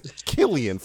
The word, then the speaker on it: Cillian: His name is Cillian, for Christ's sake. – Cillian. He's Cillian the game. – Killing it. – Because if there's one thing I've learned, is that Irish like a hard C.